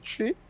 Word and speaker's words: Twi: T-W-I, you pronounce it Tri.